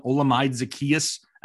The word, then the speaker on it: Olamide Zaccheaus: We're probably going to see a lot more Russell Gage, some Olamide Zaccheaus out of the slot possibly.